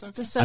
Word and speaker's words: Ahsoka: How do you say her name? – I think it's Ahsoka. – It's Ahsoka.